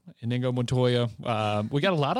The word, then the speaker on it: Inigo: And so you were right about, I can't even say his name now, but Inigo Montoya. – Yeah, it's not Indigo.